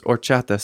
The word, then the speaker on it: horchata: I don't think you pronounce the H in horchata. – No, you do not. – Dos Amigos Horchata Soda.